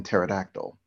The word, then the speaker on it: pterodactyl: Not a mad scientist per se, just an irked scientist who decides to rid the world of all those annoying silent letters, like the P in pterodactyl.